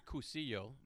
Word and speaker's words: Cursillo: And the word Curcio, it's spelled C-U-R-S-I-L-L-O.